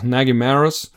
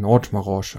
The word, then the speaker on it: Nagymaros: Wie spricht man das genau aus? Ja, die Amis sagen einfach Nagymaros. Und dann haben wir lange geglaubt, dass das Nagymaros und das heißt anscheinend Nagymaros.